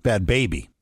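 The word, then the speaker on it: Bhad Bhabie: So Bad Bobby, am I saying that right? Bad Bobby, right? She's the Catch Me on the Outside girl from Dr. Phil. – Bad Baby. – Bad Baby? – Yeah.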